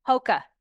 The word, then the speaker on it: Hoka: Tell me how to say it. Hoka? – Hoka.